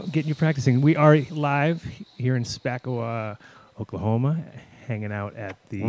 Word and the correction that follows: Spavinaw: Where? Say that again. – Spavinaw. – Spell that. Oh, Spavinaw. I said Spavinaw.